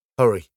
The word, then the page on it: hurry: British English